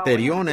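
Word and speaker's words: Terión (θηρίον): Puede ser que pronunciado Zoom, Z-W-O-N. ¿Cómo se pronunciaría eso? Zoom, son, diría yo. Y terión es T-H-E-R-I-O-N.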